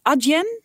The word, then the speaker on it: Adyen: Zijn ze eigenlijk te vergelijken met Adyen? Of hoe spreek je dat uit? Ja, klopt.